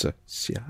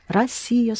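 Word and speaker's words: Россия: The O is pronounced A, as in Russia, the way we'd say it in English. – Yes. – But with a S. Rossia. Rossia. Mm-hmm. – Right. Rossia, spelled with O, but pronounced A.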